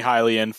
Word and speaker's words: Hylian: So like Hylian, which is now they pronounce Hylian. And I was like, oh, that's interesting because I've been mispronouncing that in my head for my entire life. So no, it's definitely Hylian.